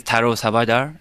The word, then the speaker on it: Taro Sawda: Okay, so my name is Taro Sawda, a Japanese diplomat for political as well as public affairs.